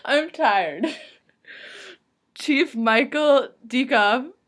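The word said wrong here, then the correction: Diekhoff: But it's spelled D-I-E-K-H-O-F. – It doesn't matter. You know he got bullied. – Dykoff? – So it's probably Dykoff. Oh, that's Dykoff.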